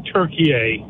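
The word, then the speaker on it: Türkiye: Turkey. – Okay. – Turkey. – It's what? – Turkey. You're emphasizing, in Arabic, the word is Turkey. It's always been Turkey. It's never been Turkey.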